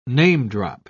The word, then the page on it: namedrop: British English